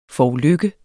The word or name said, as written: forulykke